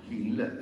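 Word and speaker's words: Gil: Hill no, Hill, scritto Cilla per gli italiani,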